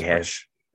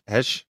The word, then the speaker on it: Heche: Somebody say Heche. – Heche. Okay. And that works.